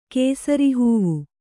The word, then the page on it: ಕೇಸರಿಹೂವು: Kannada